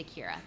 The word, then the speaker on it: Kiera: So my name was actually Keira. That was the name my parents gave me when I was born. Uh-huh. And after like 3 months, they're like, that is way too much work. And they just switched it to Keira. They just dropped a syllable. So that's why my name is K-I-E-R-A.